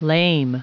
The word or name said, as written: lame